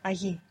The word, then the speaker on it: allí: Spelt A-H-I. And there also exists ahí spelt with double L. There's a tiny, even imperceivable difference between I, A-H-I, and I, A-L-L-I.